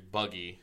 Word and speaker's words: Buggy: To be fair, it is a long U, and then we meet one of the next characters, Buggy, who's the next big antagonist, Buggy the Clown, and that is a short U.